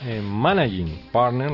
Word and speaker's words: managing partner: Llega el momento ya de saludar a nuestro invitado de hoy, que no es otro que Samuel Pimentel, que es el managing partner, lo diré bien, para España y Latinoamérica de Ackermann Beamon Group.